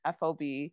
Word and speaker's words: FOB: Valerie on Twitter had shared that like FOB stands for forward operating base, and like you don't necessarily say the letters, it's pronounced like a fob, like a key fob.